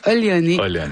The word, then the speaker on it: Oléoné: On fait la joke à cause que c'est comment que ça s'écrit en français, mais ça se prononce Oléoné en Abénaki.